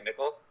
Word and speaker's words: Mikkel: I feel like Mikkel has left enough of an imprint is that how you say his name, right, Mikkel? – Yes.